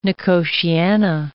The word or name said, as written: nicotiana